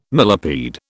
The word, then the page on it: millipede: British English